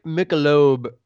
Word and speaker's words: Michelob: Is that, am I saying that right? Are you familiar with this? – You could see it. Michelob. – Michelob. I'll stick with Michelob. – Michelob. Michelob is fine too, though.